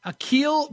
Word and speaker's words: Akil Badu: Akil Badu. – Akil Badu. – I hope I'm saying it right. His first name, A-K-I-L, that's Akil. – Akil Badu, yeah. – Badu, B-A-D-D-O.